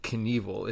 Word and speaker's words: Knievel: I don't know why we call it Knievel. It's spelled K-N-I-E-V-E-L. You think it'd be Knievel? Like K-N to start a word? The K, when do you ever say the pronounce the K?